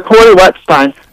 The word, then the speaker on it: Cory Wettstein: Cory Wettstein.